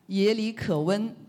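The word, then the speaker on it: 也里可温: 雅里可温呢，其实它的发音呢，其实就像耶和华上帝，耶和华那个发音。 – 耶里可温就是耶和华上帝的，你们可以试试这个发音很像。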